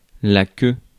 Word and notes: brush: /bɹʌʃ/; American English

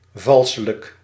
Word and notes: falsch: /falʃ/

